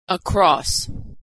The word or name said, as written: across